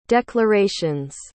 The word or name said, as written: declaration's